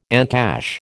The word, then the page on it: encash: American English